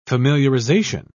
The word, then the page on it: familiarisation: British English